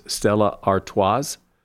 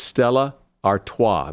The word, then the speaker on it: Stella Artois: The drink, Stella Artois. A lot of people pronounce that last S as Stella Artois, but it's Stella Artois. The last S is silent.